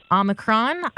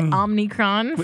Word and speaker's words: Omicron: Okay, so among 2021's most mispronounced words, Omicron. I was very guilty of calling it Omnicron for a couple days better.